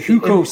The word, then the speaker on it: Hukou: The Hukou system, yeah.